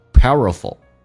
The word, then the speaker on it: powerful: Feel compelled to live 跟 a 有一个零叫 liver, powerful, 我们知道元音内部之间也会有这个连音的部分，那么 power 跟 a 一般中间加个 w 半元音作为滑动，叫 power, powerful, 而且 ful 中间一般在英式发音会有个 a, 但美式发音 a 跟 l 在一起， a 会被 l 所吸收，所以直接发成 f 跟 l, 叫 powerful,